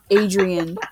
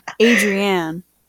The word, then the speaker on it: Adrienne: It is not Adrian. Her name is Adrienne.